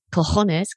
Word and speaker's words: cojones: Cojones, claro.